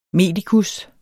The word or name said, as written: medikus